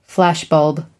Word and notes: flashbulb: American English

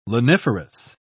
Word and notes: laniferous: British English

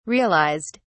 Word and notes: realised: British English

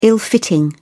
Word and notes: ill-fitting: British English

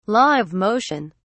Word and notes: law of motion: British English

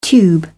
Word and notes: tube: British English

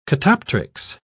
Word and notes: catoptrics: British English

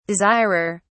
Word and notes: desirer: British English

